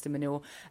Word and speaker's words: de Minaur: But I think all their hopes really do rest on Alex de Minaur. I keep accidentally saying manure when I say his name.